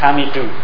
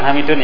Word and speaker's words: Hamidu: Nah, ya misalnya sebagai contoh, kalau misalnya ada perempuan namanya, misalnya namanya Hamid dalam bahasa Arab, maka bacanya apa? Hamidu, bukan Hamidun ya. Hamidu.